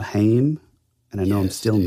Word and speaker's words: Haim: Like, I know there's like a lot of folks who listen to like heavier stuff that might think us liking, and the correct way to pronounce their last name is Haim.